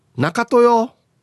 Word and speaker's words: なかとよー: B、中豊。はい、かっこ、だからさ、のイントネーションです。だからさ、中豊。あ、中豊か。